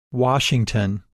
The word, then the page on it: Washington: English